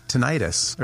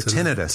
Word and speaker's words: tinnitus: Tinnitus or tinnitus. So depending on how you want to pronounce it. – Yeah. I like tinnitus.